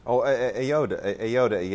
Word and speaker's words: Ayoade: Ayoade.